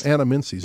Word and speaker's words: anamensis: You've got the pronunciation. Anamensis. How about that? – Yes. – Anamensis. Perfect.